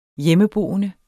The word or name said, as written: hjemmeboende